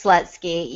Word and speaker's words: Slutzky: it, it's Slutzky.